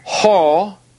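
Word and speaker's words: ho (ὁ): Now, in the Greek, these are pronounced ho, he, to.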